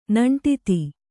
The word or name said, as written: ನೆಂಟಿತ್ತಿ